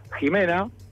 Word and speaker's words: Ximena: y dio la casualidad que justo estaba llegando Ximena, se pronuncia con J, me dijo.